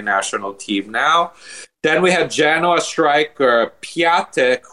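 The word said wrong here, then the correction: Piątek: who I learned you actually pronounce his name Piontek.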